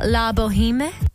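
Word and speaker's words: La Bohème: Baz Luhrmann and Catherine Martin's original neon red L'Amour sign for Opera Australia, La Boheme. Am I saying that correctly? – Yeah.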